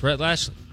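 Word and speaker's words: Rhett Lashlee: Is SMU, is that Rhett Lashley? Did I say that right? – Yes, Rhett Lashley.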